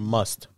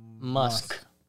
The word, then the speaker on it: Musk: Is Musk or must? – Musk. Spell it. – M-U-S-K. Musk.